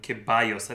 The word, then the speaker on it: Ceballos: And Ceballos, I think that's how you say it. – Yeah. – 16 million. I took Spanish at Benicio in like 8th grade, so I know the double L's are So it helps.